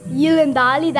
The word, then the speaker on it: Yilindali: The Gamilaroi totem is Yilindali, that's how you say it there, but it's a goanna.